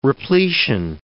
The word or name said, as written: repletion